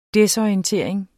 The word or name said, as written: desorientering